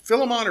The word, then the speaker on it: Philemon: Philemon or Philemon? I've heard it pronounced both ways, and I go both ways with it. So, but we'll go with Philemon. That just rolls a little better.